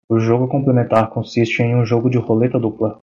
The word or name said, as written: complementar